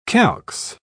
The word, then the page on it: calx: British English